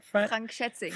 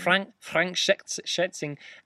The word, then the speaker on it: Frank Schätzing: Frank Schätzing. – Frank Schätzing.